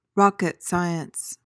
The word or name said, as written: rocket science